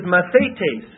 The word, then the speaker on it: mathetes: The Greek word is mathetes. M-A-T-H, long A, T, long A, S. Mathetes.